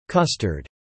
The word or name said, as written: custard